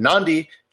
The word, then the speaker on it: Nadi: And Nandi, just if you're checking, is spelled N-A-D-I. And if you're wondering where the second N is from, that's just how they do it.